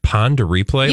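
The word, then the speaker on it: Pon de Replay: How do you say this last one? – Pond a replay? – Pond a replay? – Yes. – Like Ponderosa? – Yes.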